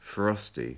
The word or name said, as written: frosty